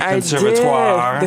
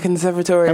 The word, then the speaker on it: conservatory: The conservatoire. – The conservatory. – Emphasis on the first syllable.